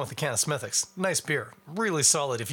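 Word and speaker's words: Smithwick's: And you probably call it Smithwix. But in most cases, we don't pronounce the W in those British place name pronunciations, or Irish in this case. So, for instance, it's not Chiswick bitter, it's Chiswick. And it's not Smithwix, it's Smithix. I don't know why they don't pronounce the W.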